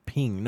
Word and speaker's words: PNG: So, it is pronounced pin, ping. No, I think that's ping. I think it's right. Or spelled out as PNG. It goes both ways.